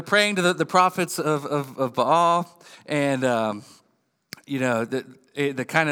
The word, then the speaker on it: Baal: the prophets of Baal. I think it's pronounced Baal, but if I say that, people look at me weird.